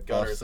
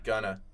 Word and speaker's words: Gunna: What'd you think of Gunner's album? Because I didn't Gunner's. – Listen to it. Gunna.